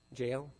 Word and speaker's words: Jael: Jael, J-A-E-L, right?